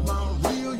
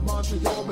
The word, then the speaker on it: Montreal: We pronounce it Mount Real, y'all can call it Montreal.